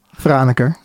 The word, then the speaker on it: Franeker: Uh, forgive me if I say these names wrong, but Freneker. Um, is that how you say it? – Ah, okay, yeah. Mm. Franeker, yeah.